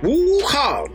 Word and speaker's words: Wukong: That's how you say it, right? – Wukong. Yeah, Wukong.